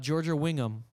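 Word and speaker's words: Georgia Whigham: Georgia Wingham. Am I saying that right? Yeah.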